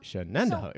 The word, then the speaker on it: Shenandoah: Shenandoah. So, right. Yeah. – Well, when Tony said it, I thought... – You cringed. I knew. – I cringed, but I thought he has to know the word Shenandoah.